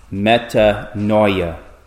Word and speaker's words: metanoia: In the Greek, it is pronounced metanoia,